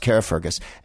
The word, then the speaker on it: Carrickfergus: Is it Carrickfergus? Yeah, I'm pronouncing all the letters like an American. It's spelled Carrickfergus. It's pronounced Carrickfergus.